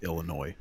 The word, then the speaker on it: Illinois: I was gonna say, yeah, I believe the S is silent. Young cultured fuck. – Silent, buddy. It's just Illinois.